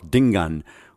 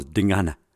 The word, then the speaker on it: Dingana: Dingaan's Zulu accent, though, was Kwabe, he spoke in the Amalala style, the one that Shaka had joked about so much, calling himself Dingan, whereas the official pronunciation among Zulu perfectionists was Dingana.